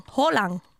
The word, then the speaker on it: Haaland: Du kan säga å. Erling Haaland.